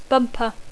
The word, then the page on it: bumper: English